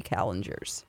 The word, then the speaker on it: Marie Callender's: Most people would call it Calendars, but my mom says Marie Callender's, and we lived in California, and I know the first restaurant is by the Queen Mary, so I think it's pronounced Marie Callender's.